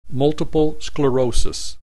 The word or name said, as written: multiple sclerosis